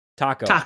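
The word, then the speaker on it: Taako: You follow three characters: Magnus, Tycho, and Merle. I think I pronounced Taco. – Taco.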